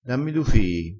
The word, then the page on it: du: dialettale